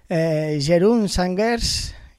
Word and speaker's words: Jeroen Sangers: Jeroen Sangers, creo que lo he dicho bien. ¿Qué tal, Jeroen? Sí, lo has dicho bien.